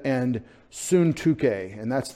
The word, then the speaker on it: Syntyche: And I encourage, this is going to be harder for you, S-U-N-T-U-C-H, long E, Syntyche. Syntyche. Syntyche is how they said her name.